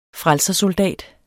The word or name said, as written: frelsersoldat